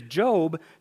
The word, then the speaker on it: Job: It's spelled J-O-B, but it's not Job, it's Job.